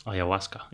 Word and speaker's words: ayahuasca: Ayahuasca. Ayahuasca. – Ayahuasca, ja.